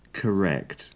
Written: correct

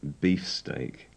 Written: beefsteak

